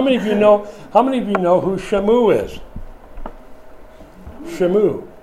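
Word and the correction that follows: Shammua: Or excuse me, I'm not pronouncing it. It's Shamu-ah. Shamu-ah.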